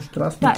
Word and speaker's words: Strażni: Ne, Strażni klingt schon ganz gut. Strażni. Strażni. – Das Z ist unser stimmhaftes Sch, Garage, ne? Wenn das einen Haken drüber hat.